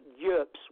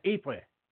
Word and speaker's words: Ypres: I don't know if, what, Yerps, whatever. – He's probably... Ypres. – Sorry? – Yeah, Ypres. – Ypres, okay. – That's how you pronounce it.